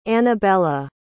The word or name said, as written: anabella